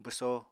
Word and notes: bwso: apatani

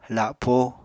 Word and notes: lapó: apatani

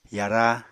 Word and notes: yara: Apatani

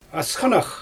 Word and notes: asxanax: Xyzyl

